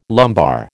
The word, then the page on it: lumbar: American English